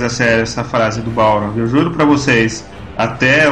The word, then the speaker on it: Balrog: Balrogs não tem asas. E a pronúncia é Balrog.